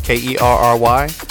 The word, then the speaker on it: Kerry: The name is spelled K E R R Y P O Y N T E R.